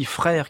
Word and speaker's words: Freire: On a eu l'occasion de faire quelques jeux de mots sur ce nom de famille, Frère, qui s'écrit, je vous rappelle, F-R-E-I-R-E. Et cela ressemble aux frères français, comme frère et sœur.